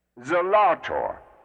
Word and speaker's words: Zelator: Zelator. Zelator. Zelator. Not Zelator, as is commonly used, but Zelator. I suppose this really is the English as opposed to a common American pronunciation. But technically it should be Zelator.